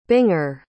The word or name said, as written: binger